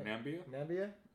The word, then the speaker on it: Namibia: Namibia.